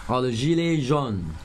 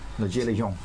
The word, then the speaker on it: le gilet jaune: Le gilet jaune。 – Le gilet jaune。